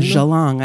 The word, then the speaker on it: Geelong: Geelong. I even looked up the pronunciation. – What is it? It's spelled G-E-E-L-O-N-G. And thank God I looked up the pronunciation because I would have been like, Geelong? – Geelong.